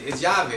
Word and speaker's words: Yave: Yave, it means key in Spanish, but we spell it Yave because we want it to roll off your tongue.